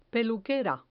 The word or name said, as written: peluquera